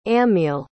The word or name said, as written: ammiel